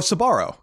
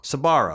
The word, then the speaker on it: Sbarro: Forgive me if I wanted to ask about the pronunciation of this place. Sparrow. Sparrow? Oh, Sbarro. Sbarro. Sbarro. Yeah.